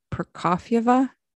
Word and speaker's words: Prokofieva: And the woman in the video is a woman by the name of Alia Prokofaya. Is that how you pronounce that? – I mean, I'm not too good at my Russian pronunciation, but I think it's Prokofieva.